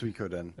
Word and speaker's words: Suikoden: am I pronouncing it right? Dan Kiefer saying. Is it Swekoden? – Swekoden. Because no, you had it right. It's Swekoden.